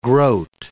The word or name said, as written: groat